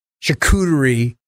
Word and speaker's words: charcuterie: And a charcuterie. Is that how you say it? – Charcuterie. – Damn it. I can never get that word right.